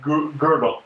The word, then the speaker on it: Gödel: Yeah, and it's got those, what are those dots? Umlauts. – Umlauts. – Over the O, so it's Gödel.